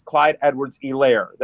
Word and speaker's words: Edwards-Helaire: But I've been told, actually, it's pronounced Clyde Edwards-Helaire, that the H is sort of silent.